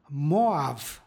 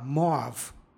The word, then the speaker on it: Moav: stiha, pročitali smo Moav. Ovdje bi slovo as, posle vokala, trebalo da ima izgovornu vrednost ja. Međutim, u srpskom jeziku ovdje izgovaramo a, Moav.